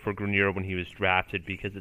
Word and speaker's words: Grenier: I had Grenier, sorry for the mispronunciation earlier. Don't know where I got Grenier from.